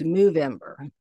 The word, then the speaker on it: Movember: And it is, as I'm pronouncing it, it's not November, it's Movember, Movember.